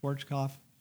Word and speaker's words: Schwarzkopf: Some of you won't remember General Schwarzkopf. Did I say that right, Jim? Schwarzkopf? Yeah.